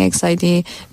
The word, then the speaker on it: EXID: You pronounce it EXID. – Yes, exit or EXID, yeah, most commonly EXID, B1A4, yes.